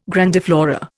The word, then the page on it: grandiflora: Indian